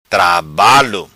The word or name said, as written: Trabalo